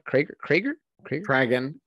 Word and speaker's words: Kraglin: I felt like the Krager performance was great. Did I say it right? Kraglin.